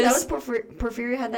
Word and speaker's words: porphyria: So the main disease that people think really sparked these vampire superstitions was porphyria. I think I'm saying that right. – Yeah, sounds right.